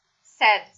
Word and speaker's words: said: Vi faccio notare che la pronuncia del verbo dire al passato è simile all'aggettivo che significa triste, però c'è un po' di diversità in realtà, perché l'aggettivo triste, scritto S-A-D, viene pronunciato con una vocale lunga e suona in questo modo, sad. Mentre qua abbiamo una pronuncia breve, quindi say, said, said.